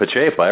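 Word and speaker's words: Pacepa: You nailed Pachepa. Everybody messes that up, but you got it just right.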